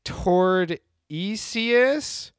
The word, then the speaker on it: Tordesillas: Tordesillas.